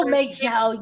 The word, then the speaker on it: Majel: It's Majel, right? That's how you say it. Oh, Majel. Yes, yeah.